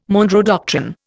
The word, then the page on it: Monroe doctrine: Indian